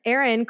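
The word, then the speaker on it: Ehren: It is, of course, directed by Wes Craven, and we have a new writer here, Aaron Kruger, and it stars some of our Freddy's brother? – Exactly.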